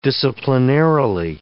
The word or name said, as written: disciplinarily